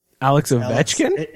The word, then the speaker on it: Ovechkin: Also, how do you pronounce Alex Ovechkin's name? – Alex Ovechkin? – It's Alex Ovechkin, and I mean, we've moved on.